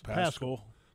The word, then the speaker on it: Zach Pascal: Because we were actually trying to pronounce Zach Pascal. – Pascal's the wide receiver. We actually were trying to do the pronunciation for the game. We said, it rhymes with Rascal, so it's Pascal.